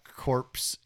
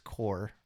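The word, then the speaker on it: corps: It's not corpse. It's core. It's pronounced.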